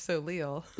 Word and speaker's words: Soleil: If you're saying it right, it's Soleil.